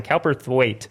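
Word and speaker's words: Cowperthwaite: I had to look up how to pronounce her name online. Cowper-Thwaite.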